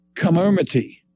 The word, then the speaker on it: Comirnaty: And then you get the Pfizer shot, and the Pfizer shot will be called Comirnaty, right? And I'll spell that again. Right? None of these things are easy to pronounce. It's C-O-M-I-R-N-A-T-Y, Comirnaty, okay?